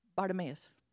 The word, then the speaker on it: Bartimaeus: Did I say that right? Bartimaeus? It sounds weird. Huh? Bartimaeus. Mm-hmm.